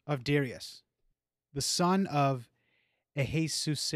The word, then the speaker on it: Ahasuerus: A Ahasuerus. – Ahasuerus. That's why there's that dash there. It's a break. The break.